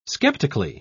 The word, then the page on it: skeptically: British English